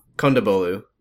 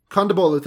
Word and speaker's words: Kondabolu: Hari Kondabolu? – Kondabolu. – Kondabolu, thank you.